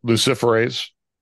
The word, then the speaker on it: luciferase: Do you know what Luciferon, I think that's how you say it, Luciferon. – Luciferase. – I can't even say it right.